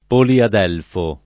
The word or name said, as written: poliadelfo